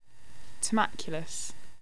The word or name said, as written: turmaculus